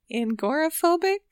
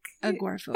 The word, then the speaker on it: agoraphobic: Uh, I always say this wrong. Agoraphobic. Agoraphobic.